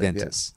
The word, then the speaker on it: Juventus: But is it pronounced Juventus? Juventus? Juventus. Believe, Juventus. Yeah.